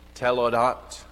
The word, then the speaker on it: toledot: Okay, it's spelled T O L E D O T, telodot or telodot. That word literally means generations or descendants.